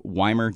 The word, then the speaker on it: Wimer: We're all over Texas, but I was told I have to spell out the name because people try and spell it like German cities. it's Chuck Weimer, W-I-M-E-R, music for the socials, and then, of course, just Chuck Weimer for the Spotify, Apple Music, and all that.